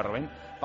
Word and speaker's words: Robin: En balonmano, el Barça ha fichado al portero francés Robben, se escribe Robin, se pronunciará Robin, para sustituir a Sterbik, que es baja por 3 meses por una lesión en la rodilla.